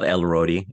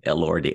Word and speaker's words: Elordi: Oh, it's not Elrodi, Elordi.